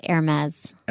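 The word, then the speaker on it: Hermès: It's spelled H-E-R-M-E-S, but it's pronounced Hermes.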